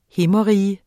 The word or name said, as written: himmerige